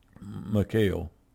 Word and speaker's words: Michal: But his wife, Michal, is a better pronunciation. I've heard both ways, but it was Saul's daughter.